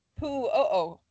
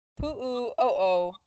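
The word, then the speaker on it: Pu'o'o (Puʻu ʻŌʻō): Beginning of the year, January 3rd, the East Rift Pu'o'o Cone, which is spelled P-U'U'O, it erupted. – Will you say it one more time? Not the spelling, but just the word. – It is the Pu'o'o cone. – Okay. – Pu'o'o cone.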